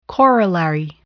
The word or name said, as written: corollary